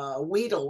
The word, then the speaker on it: Weedle: You love Weedle, whatever his name is. It's two E's, long E.